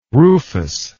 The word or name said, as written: rufus